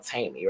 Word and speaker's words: Taimi: I've been having this experience on this uh this dating app called Taimi, right? It's called Taimi.